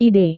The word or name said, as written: ide